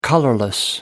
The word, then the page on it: colorless: American English